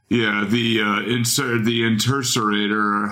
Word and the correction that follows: Interocitor: How do they pronounce it? – Interocitor. – No, the Interocitor that he has in both the films and the video, Dare to Be Stupid, and his film, UHF.